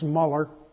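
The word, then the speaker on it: Müller: You've heard his name, George Mueller, probably pronounced Muller.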